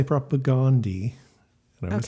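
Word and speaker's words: Propagandhi: And I will continue to say propagandy. – Okay, but it's also how the band says it.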